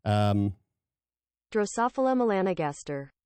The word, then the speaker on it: Drosophila melanogaster: Drosophila melanogaster.